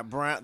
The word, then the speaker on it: Braun: I mean, he can get 20 points for What about, how do you say his name? The Braun for Denver? – Brown. They call him Brown. – It's Brown.